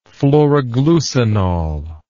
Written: phloroglucinol

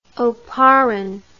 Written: oparin

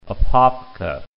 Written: Apopka

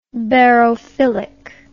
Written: barophile

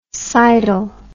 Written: cidal

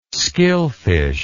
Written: skilfish

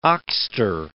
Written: oxter

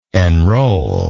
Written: enroller

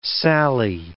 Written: sally

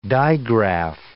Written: digraph

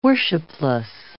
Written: worshipless

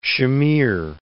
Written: Shamir

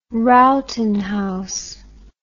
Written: Rowton house

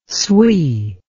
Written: sui

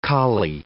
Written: colly

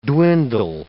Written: dwindle

